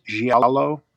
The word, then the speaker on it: giallo: Is it pronounced giallo? – Yeah, yeah. Mm-hmm.